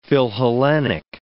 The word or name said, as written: philhellenic